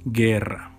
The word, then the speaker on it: guerra: Or like in the word guerra, it's the word for war, and we say guerra, it's G, U, I, sorry, G, U, E, ge, ge, we don't say gue.